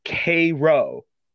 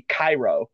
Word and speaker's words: Cairo: And yes, I did not mispronounce that. It is the USS Cairo. It is named after a city in southern Illinois, and that is literally how they pronounce it. And you will get very, very cross with that person if you say it like how in Egypt it would be Cairo.